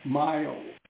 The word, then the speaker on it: Miles: My name is Miles. – Maya? – Miles. M-I-L-E-S. – Miles. Okay, sorry. Spell it M as a Michael. – I-L-E-S.